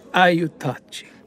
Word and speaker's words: Aiutaci: Aiutaci. – Says this with Italian inflection, accent on the second syllable.